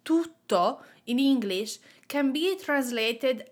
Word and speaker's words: tutto: Let's start with tutto as an adjective and make sure that you are pronouncing that double T in the middle, tutto.